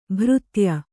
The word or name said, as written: ಭಾರತೇಯ